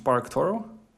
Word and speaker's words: SparkToro: I think it's pronounced SparkToro.